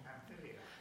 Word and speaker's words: Pamphylia: Pamphylia.